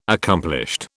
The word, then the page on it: accomplished: British English